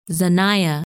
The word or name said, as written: Zaniah